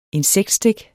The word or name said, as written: insektstik